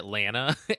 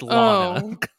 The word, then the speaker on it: Lana: You keep pronouncing it Lana. It's Lana. Oh, okay. – It just sounds like you're calling her Llama, like a llama.